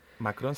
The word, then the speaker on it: Macron: Macron, sí.